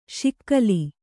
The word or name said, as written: ಶಕಲಿ